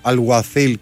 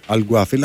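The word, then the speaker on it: Alguacil: Λοιπόν, ο Γιάννης λέει εδώ πέρα ότι προφέρεται Αλγουαθίλ και όχι Αλγουαθίλ, αλλά βέβαια η ΠΑΕ τον έχει γράψει με κάπα.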